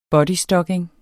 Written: bodystocking

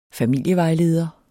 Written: familievejledning

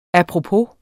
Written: apropos

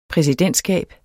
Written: præsidentskab

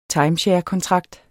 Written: timesharekontrakt

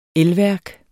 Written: installationsprogram

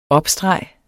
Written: opstreg

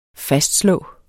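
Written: FASTSLÅEDE

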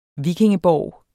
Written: VIKINGEBORGE

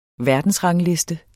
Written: verdensrangliste

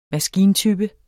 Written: maskintype

